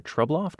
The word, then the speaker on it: Trubloff: Also, The Lion and the Bird by Mary Ann, Dubuc, D-U-B-U-C. And then Susanna in Seattle, Washington, recommending John Birmingham's, another pronouncer one here, Trubloff, T-R-U-B-L-O-F-F,